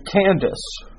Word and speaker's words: Kandake: It's pronounced Kandake, is the lady. Now, in Greek, they say Candace, and it's a regent for a young king.